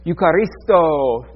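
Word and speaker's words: eucharisto (εὐχαριστῶ): This one is the Greek word eucharisto. Roll your R, it doesn't count. Eucharisto. Okay, it counts even if you didn't.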